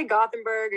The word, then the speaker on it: Gothenburg: Well, you can say Gothenburg or Göteborg. It's just like, but say Gothenburg, that's more Americanized.